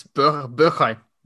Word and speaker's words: Bergwijn: Say it right. It's Bergwijn. – Bergwijn. No. Some German. – Bergwijn. – Bergwijn. There you go.